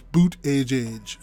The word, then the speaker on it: Buttigieg: It's very difficult to say, but I was looking at an article when I was reading up on him, and they say it's pronounced boot, like B-O-O-T, and then edge, so the word edge, and so what it sounds like is boot edge, so it's Pete Buttigieg.